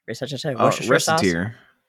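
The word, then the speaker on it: Recettear: I haven't even played, how do you pronounce it? Reseteer? – Reseteer. I just say Reseteer.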